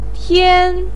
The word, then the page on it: tian1: pinyin